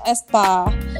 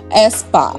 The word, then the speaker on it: Aespa: Tapi A-nya itu tuh kayak di dictionary bahasa Inggris. Jadi kayaknya E bacanya Espa.